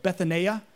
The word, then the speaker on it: Bethania: And actually, it's pronounced a little bit different. We in the King James call it Bethany, but it's actually like Bethania.